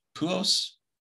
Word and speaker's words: Pujols: Pujols.